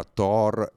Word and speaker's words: Taur: Ma c'è un articolo su Written Matter, sul mio sito, se andate sulla parte degli articoli e scrivete Taur, che si pronuncia Thor e che si scrive così, ve lo scrivo in chat, trovate un articolo dove c'è la timeline, tutti i titoli, eccetera, eccetera.